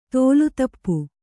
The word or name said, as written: ತೋಲುತಪ್ಪು